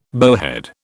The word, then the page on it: bowhead: British English